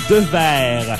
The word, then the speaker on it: Deweare: On continue avec notre portion anglophone de l'émission, avec la formation De Verre. On le prononce De Verre, selon ce qu'on m'a dit, mais ça s'écrit De Verre. D-E-W-E-A-R-E.